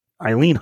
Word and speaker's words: Eileen: And Eileen Huntington was given the Entrepreneur of the Year Award.